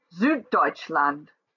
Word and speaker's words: Süddeutschland: Süddeutschland, Österreich each have 3 syllables, and the first syllable is accentuated.